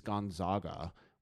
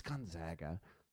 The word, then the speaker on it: Gonzaga: In this year, Gonzaga University from Spokane, Washington, which most people pronounce Spokane, and most people pronounce the university as Gonzaga, which is wrong. It's Gonzaga.